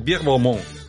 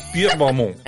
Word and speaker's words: Pierre Balmain: Es que, bueno, como pronunciado como en francés, Pierre Balmain. Sí, así, para francesarnos un poquito. Pierre Balmain.